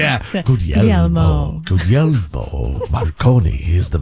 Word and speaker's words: Guglielmo: He says, Guglielmo is how you say it.